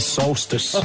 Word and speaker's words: solstice: Your fact about the winter solace. – The solace, you said. – Yeah, no, I said the solstice. – You said the solace. – S-O-L-T-I-C-E. Correct? – How do you spell it? Sorry? – Oh, I've just bloody told you. S-O-L-T-I-C-E. – No, it's the solstice. Yes.